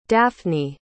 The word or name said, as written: daphnie